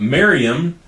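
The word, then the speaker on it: Mariamne: And so this other wife that he had, Mariam, I believe is how you pronounce her name. It's M-A-R-I-A-M-N-E.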